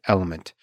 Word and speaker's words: LMNT: This episode is also brought to you by Element Electrolyte Drink Mix, spelled L M N T, but pronounced Element.